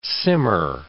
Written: simmer

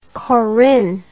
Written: corynne

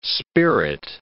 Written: spirit

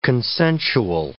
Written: consensual

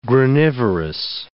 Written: granivorous

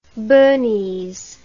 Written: burnese

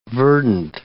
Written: verdant

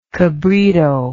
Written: cabrito

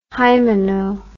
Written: hymeno

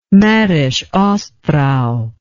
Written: Mährisch Ostrau

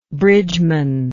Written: bridgeman

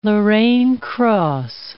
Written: Lorraine cross

